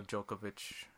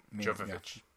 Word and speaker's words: Jovovich: Just as long as I beat it before the Mila Jokovic... How do I say her name? – Jovovich.